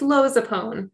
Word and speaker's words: Lozupone: It's Lozupone.